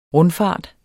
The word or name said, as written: rundfart